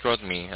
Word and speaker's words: Rodney: Yeah, my name is Rodney.